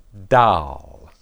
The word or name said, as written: dal